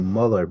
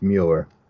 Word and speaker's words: Mueller: See, I want to keep saying Muller because that's how they, Bob Mueller spells his name, or pronounces his name. But it, yeah, it's Mueller.